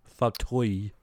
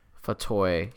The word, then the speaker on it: Fauteuil: She pronounces it Annabelle Fauteuil. It's a really weird French pronunciation that I'm not used to. Fauteuil. – Fauteuil.